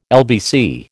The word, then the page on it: LBC: American English